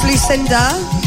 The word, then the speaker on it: Lusinda: It's Lucinda, spelt with an S.